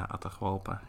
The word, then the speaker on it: Atahualpa: maar hij heeft dan dat verhaal van Pizarro aan Atahualpa, als ik dat goed zeg, zeg ik dat goed? Atahualpa. – Ja, Atahualpa.